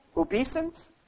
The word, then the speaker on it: obeisance: And if I say this word right, I looked it up in my dictionary and still couldn't figure out how to say it correctly. Obeisance? I was told I'm correct.